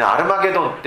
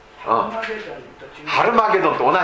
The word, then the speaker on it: ハルマゲドン: そしてアルマゲドン戦争、ね、アルマゲドンってご存知ですか。ハルマゲドンと同じです、うん、それをハッと発音するかアッと発音するかの違いだけです。